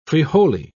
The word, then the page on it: frijole: British English